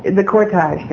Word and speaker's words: cortege: And he said he remembered watching the funeral cortege, what do you say, going to... Hi there. Mr. Pronounce that word. The cortege, thank you.